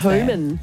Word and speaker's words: Heumann: Yeah, I think it's Human. Sorry, I haven't got the sheet up in front of me, but yes, I think I've heard it. It's Being, H-E-U-M-A-N-N. – So Human. – Play on words. – Human. – Yeah, Human.